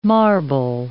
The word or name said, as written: marble